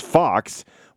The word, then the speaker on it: Fuchs: You know, it's funny, too, like Tommy Fox, so there's a lot of people in my hometown, they call it's F-U-C-H-S, so it's a lot of people in my area would call it Fuchs, but in the Glendon area, it's the German pronunciation with just Fox, which doesn't look like F-O-X at all, but so there's a lot of that.